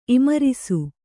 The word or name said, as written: ಇಮರಿಸು